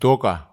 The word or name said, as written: toka